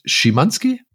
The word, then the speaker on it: Szymanski: namens David Szymanski. Spricht man das so aus? – Ich glaube ja.